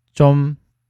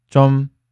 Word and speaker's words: 좀: 今天我们学习的第一句有点紧张，还有呢是 좀 떨 려 요， 좀 떨 려 요。其中第一个 좀 它是一个缩略的词，原型呢是 조 금。它的这个正确发音的话，其实。正常读松音的，但是呢，口语当中呢，一般读作紧音比较多一点。